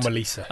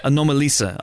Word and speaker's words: Anomalisa: Anomalisa? Am I saying that right? – Anomalisa. – Anomalisa.